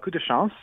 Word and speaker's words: Coup de Chance: Coup de Chance. I hope I'm not butchering that name too much. – That's right. It's called Coup de Chance, and it's a witty sort of like mystery, but it's not very heavy at all.